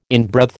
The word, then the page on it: inbreathe: American English